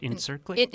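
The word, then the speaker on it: İncirlik: Incirlik. Yeah. – I tried. I knew I would fail. – C is like a J.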